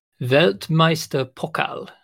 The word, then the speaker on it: Weltmeisterpokal: But that event in Germany, which you have like a Discord server called Welt, hang on, let me pronounce it, Weltmeister Pokal. – Yes, exactly. – Not bad. – Yeah, it's very accurate.